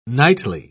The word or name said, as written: nightly